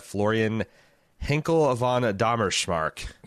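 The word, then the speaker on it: Florian Henckel von Donnersmarck: Nicholas Ragovis, he has selected for his commission The Lives of Others, a 2006 German film directed by Florian Henkel von Dommerschmark. – That can't be right, but let's go with it. – Hey, I am the resident German American, so I'm quite certain of my pronunciation there.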